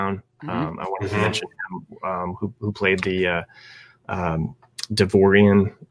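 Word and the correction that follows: Devaronian: I always mess up how to pronounce that, but his name was Berg. Davaronian.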